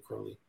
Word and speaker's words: Crowley: If you read, there's a uh, a poem that he wrote called "The Beetle," where he rhymes his na- last name with "holy." So I'd say Crowley but I don't really care how people pronounce it. – No, I used I to say Crowley, but I think it's really Crowley.